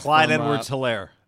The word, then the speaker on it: Clyde Edwards-Helaire: Like, I can never say his name right. Something Solaire, the little running back. – Clyde Edwards-Helaire. – Yeah, Clyde Edwards-Helaire.